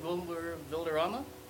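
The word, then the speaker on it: Valderrama: I had Vilderrama. Is that how you say his name? Mm-hmm.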